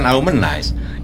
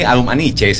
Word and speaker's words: Arumanice: Lah, kan saya bilang ini bukan Arumanize. Ini Arumanice sebelang ini.